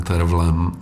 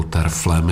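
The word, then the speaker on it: Terfel: A tahle nahrávka s Brynem Terflem, nebo Terflem, já velšskou výslovnost úplně neovládám. Někdo říká Terfl, někdo Terfl. A s Jamesem Levinem, no, tak to je taková ta parádní vypalovačka, kdy on si doopravdy s celým tím partem hraje.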